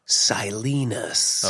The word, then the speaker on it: Silenus: And one of the things I thought was quite fascinating was how Martin Salinas or whatever the fuck it is. Salinas? – Oh my God. You know how they say it in the audiobook? – How? – Silenus.